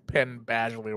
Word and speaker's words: Penn Badgley: It always comes back to fucking Penn Badgley, whatever the fuck his name is.